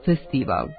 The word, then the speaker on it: festival: Imenica festival nema dvojak izgovor. Može da se kaže samo festival, a u promeni festivala, festivalu, festivalom.